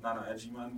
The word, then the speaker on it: Agyemang: So my name is Nana Agyemang, which is November Alpha, too corporate for spelling it all phonetically.